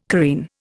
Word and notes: careen: Indian

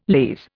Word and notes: lees: Indian

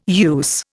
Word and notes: use: Indian

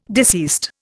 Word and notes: deceased: Indian

